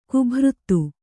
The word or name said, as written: ಗಭೀರತೆ